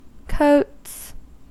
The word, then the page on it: coats: American English